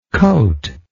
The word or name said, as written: coatless